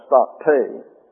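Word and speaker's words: stakte (στακτή): And then you have State is the Greek. S-T-A-K-T-E. And that accent is on the eta. Gets a long A sound, stakte.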